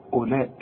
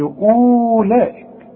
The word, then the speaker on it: أولئك: شوف أولئك، اوعى تقول لي أولئك، لا، أولئك كأن الواو غير موجودة.